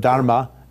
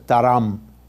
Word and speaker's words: dharma: So in Sanskrit, they don't pronounce the final ah on syllables, like as, instead of dharma, it's dharam.